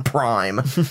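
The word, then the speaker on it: PRhyme: He's also a member of Bad Meets Evil, along with Eminem and P-Rhyme with DJ Premier. – Yeah. – Prime. Prime.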